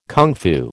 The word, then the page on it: kung fu: American English